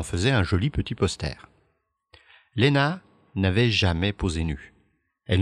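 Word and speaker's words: Lenna: Elle insiste pour être créditée sous le prénom de Lena avec deux N et non Lena avec un seul N, pour que la prononciation reste bien Lena et pas Lina, comme elle l'a sans doute déjà entendu aux États-Unis.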